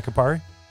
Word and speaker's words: Campari: They were bought by an Italian Campari group. Am I saying that right? You're Italian. – Yeah. Yeah. – Yeah, Campari.